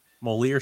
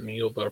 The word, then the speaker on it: Moeller: Moeller? Moeller sounds better. – I don't know why I think that people would pronounce that Mueller for some reason. – Moeller. For anybody wanting to put that into like Google Voice and have it like read back to you, there you go.